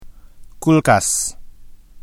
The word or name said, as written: kulkas